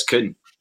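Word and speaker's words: Kühn: And I messaged a German girl that I know and just said, how do you pronounce this name? Because it's just been a bone of contention. And it's Kuhn. She came back with a voice note saying, this is how you pronounce it, because it's got the umlaut above the U.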